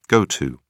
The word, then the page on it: go to: British English